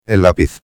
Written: el lapiz

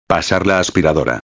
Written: pasar la aspiradora

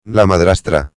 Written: la madrastra

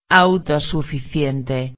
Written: autosuficiente

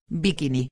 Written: biquini

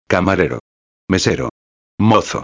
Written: camarero, mesero, mozo

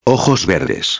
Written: verdes

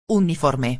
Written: uniforme

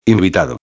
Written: invitado

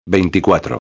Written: 24

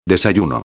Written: desayuno

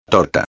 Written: torta

